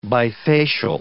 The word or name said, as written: bifacial